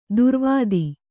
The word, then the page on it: ದುರ್ವಾದ: durvāda